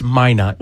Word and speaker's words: Minot: Yeah, one pronunciation. It's Minot.